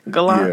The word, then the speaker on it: Gallant: Is that how you say his name? Gallant? – Yeah. Mm-hmm. Like the car.